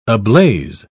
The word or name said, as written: ablaze